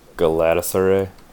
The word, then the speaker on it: Galatasaray: How do you think you pronounce that? – Galatasaray? – Yeah, you're right.